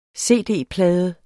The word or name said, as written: cd-plade